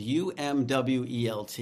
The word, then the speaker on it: Umwelt: There's a word I learned when I was researching this, and the word is spelled UMWELT. It's pronounced Umwelt.